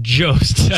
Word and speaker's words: Jost: Soft J? Jost? Jost? – Go with Jost. – Scarlett Johansson. No. Johansson? – It's very much Jost.